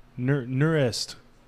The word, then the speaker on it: Nusret: Yeah, this restaurant's, I don't know how you say it, Nuts, N-U-S-R, Nurest, N-U-S-R-E-T.